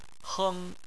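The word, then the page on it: heng: tone 1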